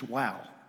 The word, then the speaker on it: waw: It says W-A-W. It's usually pronounced wow. Wow.